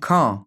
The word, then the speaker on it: Caen: Tja, und so geht es dann auch immer weiter dieser Woche in der französischen Stadt Sion. Ich hoffe, sie wird so ausgesprochen. – Nee. – Caen. Entschuldigung, Caen.